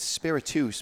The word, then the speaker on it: Spiritus: It's Spiritus, because that's how Nelson pronounces it. You dink. – Well, he should put it's a Spanish word.